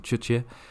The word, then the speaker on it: juche: I'm going to settle on juche. It's spelled, by the way, in the North Korean Latinization anyways, as J-U-C-H-E. But it sounds a lot more like a CH sound, juche, to my ears anyways.